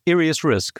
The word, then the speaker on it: IriusRisk: IriusRisk.